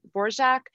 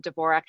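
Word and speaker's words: Dvořák: So early on on, she did want it to be pronounced um, Borjak, and I don't know that anybody ever did. – So the the, com- the very common pronunciation became Dvorak, and even early on, she seemed to not she seemed to waver on how she wanted to spell it.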